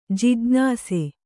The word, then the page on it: ಜಿಜ್ಞಾಸು: jijṇāsu